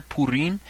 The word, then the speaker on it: Purim: The festival known as Purim, and my pronunciation there, Purim, is not like the usual pronunciation that you're going to hear in many circles. In fact, you'll hear it called Purim, Purim, Purim, Purim, something to that effect. It is, in fact, correct to say it as Purim.